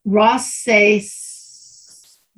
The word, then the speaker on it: Roncesvalles: Roncesvalles. – Roncesvalles.